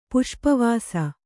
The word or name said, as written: ಪುಷ್ಪವಸೆ